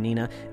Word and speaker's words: Niena: Now, this is Nina, and it's spelled N-I-E-N-A, so I'm saying Nina.